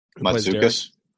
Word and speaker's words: Mantzoukas: And then you throw in there Jason I can't even say his name, Mantzoukas. – Mantzoukas.